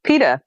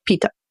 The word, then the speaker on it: PETA: PETA. – Okay, PETA. – Yeah. – Not to be mixed up with the Mediterranean flatbread. Is it similar? – Well, yeah. It's exactly the same sounding word.